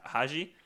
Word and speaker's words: Hajj: Hajj.